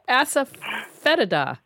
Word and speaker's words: Asafoetida: Asafoetida.